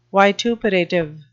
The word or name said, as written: vituperative